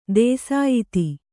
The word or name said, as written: ದೇಸಾಯಿತಿ